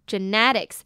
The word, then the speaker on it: genetic: Genetics is in all that drives hair loss. Long periods of stress can release signals that shock follicles and force them into the resting phase prematurely. Genetics is in all that drives hair loss. Long periods of stress can release signals that shock follicles and force them into the resting phase prematurely. 首先来看 genetic 这个字。 genetic 呢指的是起源的发生的或者是基因的 genetics 那它的重音呢是在 ne 的地方 ge呢是发ge的音 ne的e呢则是发微笑的嘴型 genetic genetic